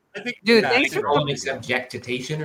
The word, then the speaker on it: jactitation: It's Jactitation.